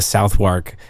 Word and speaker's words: Southwark: He was sent over here with some shipbuilders, probably from the, I'm going to pronounce it the Philly way, the Southwark area of London.